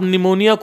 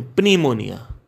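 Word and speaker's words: pneumonia: आप निमोनिया को पिमोनिया बोलो प्नी, प्नीमोन प्नीमोनिया क्योंकि पी साइलेंट है या फिर निमोनिया बोलो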